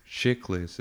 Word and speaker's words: Chiklis: All right, I moved it Michael Chiklis.